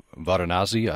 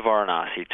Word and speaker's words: Varanasi: You went to Varanasi. I'm not sure if you pronounce it that way. – Yes. – Mother Teresa's organization there. – Absolutely. So when I went to India, I went to Varanasi to work in one of Mother Teresa's homes for the destitute and dying.